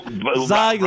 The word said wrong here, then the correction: Geltzeiler: Zeiler. No, no, no. Come on. Geltzeiler. Geltzeiler.